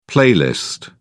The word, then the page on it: playlist: British English